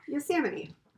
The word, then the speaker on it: Yosemite: It's right Yosemite. – At the bottom. Is that how you say it? We talked about this. – Yosemite. – And yeah, I've never... – You say it right, but the first time you say it, I don't know, it has like a little twang to it.